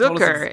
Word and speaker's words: Zucker: Is it Jason Zucker or Zucker? Now you're saying Zucker. I feel like at one point someone Zucker. – Told us... – It rhymes with snooker.